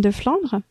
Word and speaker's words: Deflandre: I'm totally going to butcher your last name, Def- Deflon. So you're French, but you can pronounce it better than I can. Go ahead. – Yes. Hi, everyone. My name is Delphine Deflandre.